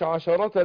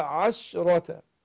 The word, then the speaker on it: عشرة: وهنا عندنا عشرة، اخد بالي من تسكين الشين، ما قلتش عشرة، لا عشرة عشرة.